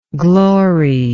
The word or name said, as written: gloryingly